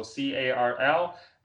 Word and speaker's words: Carl: You can just Google my name, Carl Zha. Carl is spelled with a C, so Carl, and my last name Zha.